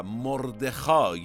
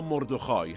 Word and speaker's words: مردخای: استر و مردخای یا مردخای هر دو تلفظ میشه گویا البته محلی های همدان میگن مردخای